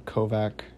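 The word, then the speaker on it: Kovach: I think it's Kovac or Kovac. It's K-O-V-A-C-H, so I believe that's right.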